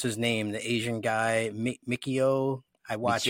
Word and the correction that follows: Michio Kaku: Michio Kaku.